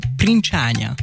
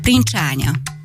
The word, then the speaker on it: princsánya: Hát még mindig a fejem maradunk, ugyanis van szemöldökünk, és ezt úgy szokták mondani, hogy princsánya. – Ó, ezek egyre szebb szavakat, ez is nagyon szép, princsánya. – Igen, ó, tökéletesen kiejtetted, pedig képzeld, hogy ez olyan nehéz szó,